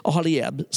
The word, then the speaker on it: Aholiab: Aholiab, son of that guy from the tribe of Dan.